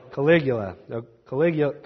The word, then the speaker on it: Caligula: Caligula.